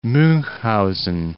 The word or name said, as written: Munchhausen